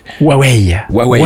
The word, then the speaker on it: Huawei: Voilà, ça se passe pas très bien pour certains constructeurs, dont je vais te laisser le prononcer parce que moi j'y arrive jamais. – Huawei. – Voilà, c'est ça.